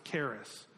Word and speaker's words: charis: Sounds like Paris, but it's pronounced charis.